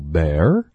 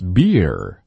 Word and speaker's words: bear: Uncle Bear likes beer.